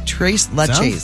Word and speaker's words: Tres Leches: Tres Leches.